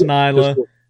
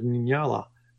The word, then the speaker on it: Nyala: I know it's the proper Nyala, but that's not how I, vocalized it inside my head when I was writing it.